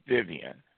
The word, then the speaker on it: Vivian: And it's under Pastor James Vivian. My last name is spelled V-I-V-I-A-N.